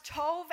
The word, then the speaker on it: tov: And the Hebrew word tov is spelled T-O-B-E, but it's pronounced tov, and it basically was a pleasurable thing.